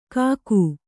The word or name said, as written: ಕಾಕು